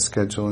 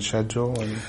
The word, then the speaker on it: schedule: Schedule and schedule are very strange because, in fact, historically, both Americans and Britons are wrong. That is to say, the historically expected pronunciation of that word is sedgel, with an initial S.